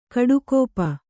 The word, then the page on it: ಕುಡಿಗಪ್ಪು: kuḍigappu